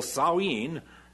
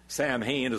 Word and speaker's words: Samhain: Now, those who will tell you that what the church actually did is they took this pagan festival, Samhain, Samhain is what it looks like, they pronounce it Samhain, and made it into a church holiday instead.